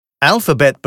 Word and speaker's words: Alphabet: Alphabet betont man im Englischen am Wortanfang.